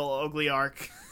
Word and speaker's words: oligarch: you have to be a Russian oil oligarch. – Yeah, oligarch, yes. That's how you pronounce the word.